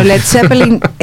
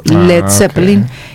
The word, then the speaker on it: Led Zeppelin: Entonces de ahí se volvió Led Zeppelin. Y claro, le quitan la A para que lo pronuncien correctamente.